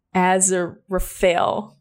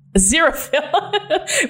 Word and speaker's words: Aziraphale: So they said, having been on Earth since the beginning and with the apocalypse now thwarted, how do you say his name again? Aziraphale. Aziraphale.